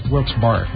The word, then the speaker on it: Wilkes-Barre: Lancaster, Malvern, Wilkes-Barre, is that how you pronounce it? – Yeah. – Wilkes-Barre. Wilkes-Barre, okay.